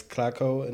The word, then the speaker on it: Klakow: Well, someone said Klakow. Klakow? Which is kind of cool, but it's Klakow in German.